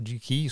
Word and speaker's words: GKE: E un altro problema invece era avvenuto con JKE e il JKE, non si dice così, GKE, più facile.